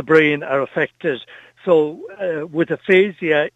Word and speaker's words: aphasia: You wanted to also highlight aphasia, or am I pronouncing that properly? – Aphasia, yes.